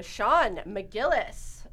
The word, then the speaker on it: Sean Macgillis: All right, so Sean Macgillis is how you say it.